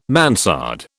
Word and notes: mansard: British English

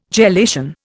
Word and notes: gelation: Indian

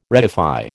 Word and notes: reify: American English

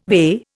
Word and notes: VE: Indian

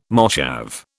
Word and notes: moshav: British English

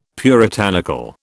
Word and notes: puritanical: British English

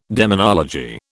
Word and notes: demonology: British English

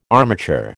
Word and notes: armature: American English

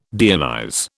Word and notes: deionize: British English

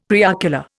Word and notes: preocular: Indian

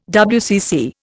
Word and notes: WCC: Indian

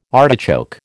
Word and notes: artichoke: American English

